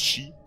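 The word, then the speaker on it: chi (四): En Asie, c'est plutôt le chiffre 4 qui leur fait horreur, pour cause, le mot se prononce chi, qui a la même prononciation que le mot mort.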